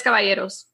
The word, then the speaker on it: Caballeros: I feel like is the only one that could really pronunciate the Caballeros. Because we all say Caballeros. Say it again. – now she's all nervous. – The Tres Caballeros. – That's how you're supposed to say it.